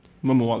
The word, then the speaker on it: Momoa: Mo- hur uttalar man det? Momoa? Momoa tror jag.